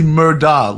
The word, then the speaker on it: Murdaugh: If his last name wasn't Murtaugh, what do you think the case would have been? – Louis, it's actually Murdaugh, like Murdaugh. It's almost like Murdaugh, like Murdaugh. It's pronounced.